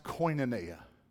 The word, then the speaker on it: koinonia: But it's a word that's pronounced koinonia.